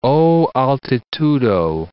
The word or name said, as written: O altitudo